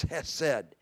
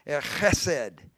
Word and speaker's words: chesed: God even describes himself as Hesed. That, and I think if I was really pronouncing it correctly, it's got that CH at the beginning, chesed, that how we'd pronounce those Jewish words.